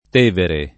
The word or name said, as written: Tevere